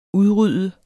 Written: udrydde

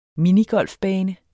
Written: minigolfbane

